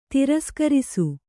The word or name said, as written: ತಿರಸ್ಕರಿಸು